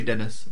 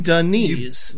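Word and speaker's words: Denis: It's the light E at the end. – It's like you're saying Denise. – But you barely pronounce the E. It's Claire Denise.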